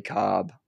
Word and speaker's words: Cobb: Billy Cobb, C-O-B-B. Billy is B-I-L-L-Y, not B-I-L-L-I-E, like some of the famous Billys spell it.